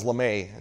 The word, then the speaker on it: Lamme: And when I listened to the voicemail, it was one of these, "Hello, Nicholas Lame." My last name is LeMay. It's spelled L-A-M-M-E.